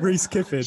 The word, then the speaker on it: Reece Kyffin: I'll get you to pronounce it. – Reece Kyffin. – So there we go. That's it. It's Reece Kyffin, Kyffin. Kyffin. – Perfect. – I'm Reece Kyffin.